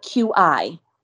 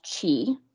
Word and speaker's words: QI: So what I called them is key skills, but QI. Not K-E-Y. And I always say the fact that it sounds like key, like, you know, K-E-Y, is good because they're really important, valuable skills. Q-I also can be pronounced chi, which is sort of the same meaning.